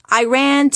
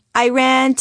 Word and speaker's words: Iran: Often kids tried to be funny by chanting, "I ran to Iran, I ran to Iran." The correct pronunciation, I always inform them, is Iran. "I ran" is a sentence, I told them, as in, "I ran away from my geography lesson."